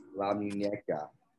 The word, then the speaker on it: La Muñeca: Okay, next we have the WWE SmackDown Women's Champion, the EST, Bianca Belair, taking on, how do you say it again? La Muñeca? Yes. La Muñeca.